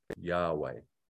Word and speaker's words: Yahweh: And that's captured by those four letters that we don't quite know how to pronounce, but we say is Yahweh. Jehovah is a mispronunciation of that.